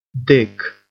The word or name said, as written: dick